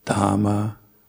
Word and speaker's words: Dhamma: So try to pronounce all the H's, even though it's difficult. As I said a couple times before, we usually don't pronounce H's. Unless it says the start of a word. So, Dhamma, Buddha, and Sangha.